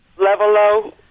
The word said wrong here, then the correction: Lenovo: Lenovo. – Oh, right, yeah, Lenovo.